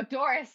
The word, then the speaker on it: Douris: Douris.